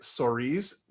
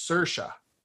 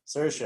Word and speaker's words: Saoirse: I always say it Soriz because I thought that's how you say her name. – Uh-huh. – It's Saoirse. – Saoirse, exactly.